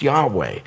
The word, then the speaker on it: Yahweh: So this phrase, this name, "I am who I am," was the phrase that became the name of God, Yahweh, this pronounced Yahweh.